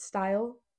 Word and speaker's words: STYL: It's called, I think you pronounce it style, but it's like spelled without the E.